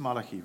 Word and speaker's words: Malachi: Mose bis Malachi, right? Is that how you say it? – Yes.